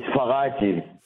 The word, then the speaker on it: Faraggi: It's Faraggi.